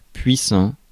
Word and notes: puissant: France; /pɥi.sɑ̃/

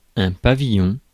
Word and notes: pavillon: [pa.vi.jɔ̃]; France; French

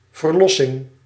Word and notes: release: /ɹɪˈliːs/; [ɹɪˈliːs]; /ɹiːˈliːs/

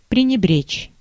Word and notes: scorn: /skɔː(r)n/; [skɔːrn]; /skɔːn/

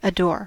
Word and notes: adore: /əˈdɔː/; /əˈdɔːr/

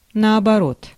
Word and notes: наоборот: /nəːbɐˈrot/

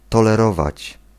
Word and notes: tolerate: /ˈtɑl.ə.ɹeɪt/; American English; GenAm

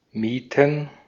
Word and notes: hire: American English

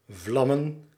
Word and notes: bucket: /ˈbʌ.kɪt/

